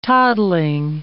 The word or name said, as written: toddling